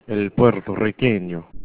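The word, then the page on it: puertorriqueño: spanish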